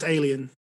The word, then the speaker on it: La Haine: I would ask them, first of all, if they've seen La Haine, which is a very good French film. – I don't want to embarrass you, but it's pronounced alien.